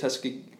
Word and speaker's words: Tuckasegee: Tuckasegee.